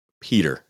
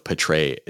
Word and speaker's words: Pitre: And that is Jalen Peter. And I just said Peter, but I'm going to go with Pitre just in case I said it wrong.